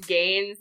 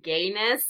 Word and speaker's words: Gaynes: I have to admit to laughing out loud at George Gaines' name. You guys are pronouncing it well, but to me it read like George Gayness.